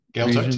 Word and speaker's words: Gaeltacht: but Gaeltacht. – Gaeltacht, yep.